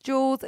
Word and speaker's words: Juelz: even though it's pronounced Jules, it's spelled J-U-E-L-Z, and it's constantly, Many people get it right on the text line.